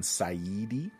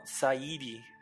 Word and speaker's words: Saeedi: I'm probably not pronouncing it right, because it's like S-A-E-E-D-I. So the AE should be Say, and then the EDI should be AD, I believe. – Going by Lost, I would have gone Saidi. – Oh, Saidi. Yeah, that works. Saidi. Yeah, that works too.